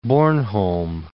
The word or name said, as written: Bornholm